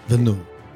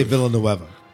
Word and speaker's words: Villeneuve: I still want to say Villanueva, but it's not it.